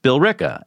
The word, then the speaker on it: Billerica: We have so many towns like that in Massachusetts that we pronounce not the way it's spelled, like Billerica is pronounced Billerica, and Worcester is Worcester, and Gloucester is Gloucester, and Boston.